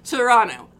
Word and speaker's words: Toronto: Time I see it, I'm like, Toronto. I'm a native. – You're not going to pronounce that last T.